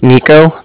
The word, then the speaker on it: Niko: My name is Nico. That's spelled N-I-K-O.